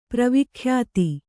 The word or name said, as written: ಪ್ರವಿಖ್ಯಾತಿ